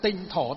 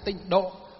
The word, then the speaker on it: tịnh độ: Nguyên và âm của nó đọc đúng theo chữ Hán. Chứ vay nghe đen là tịnh thổ tông. Đấy, chúng ta đọc là tịnh độ tông.